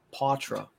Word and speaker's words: Poitras: Now, what did you guys think of Matthew Poitras, the Poitras? I think that's how you say it.